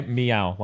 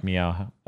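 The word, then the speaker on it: Miyao: The director on this is Yoshikazu Miao. – Can you spell that? – It's M-I-Y-A-O. – Okay, Miao, like Miao.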